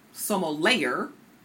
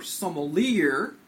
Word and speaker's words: sommelier: Some of you may have seen the word sommelier listed on the wine list. No, it's not pronounced sommelier or sommelier. It's French, sommelier.